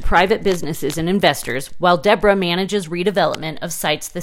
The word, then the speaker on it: DBRA: DBRA, which is pronounced like Deborah.